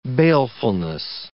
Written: balefulness

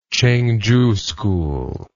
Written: cheng chu school

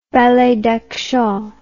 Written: ballet d'action

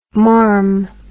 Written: marm

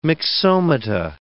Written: myxomata

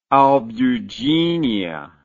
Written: albuginea

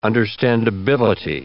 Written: understandability

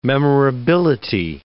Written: memorability

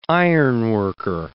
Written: ironworker

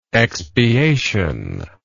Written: expiational

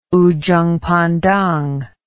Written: ujung pandang

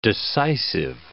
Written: decisive

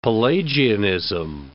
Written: Pelagianism

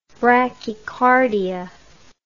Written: brachycardia

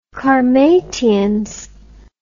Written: qarmatians

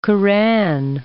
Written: Quran

